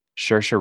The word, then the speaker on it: Saoirse: Saoirse Ronan.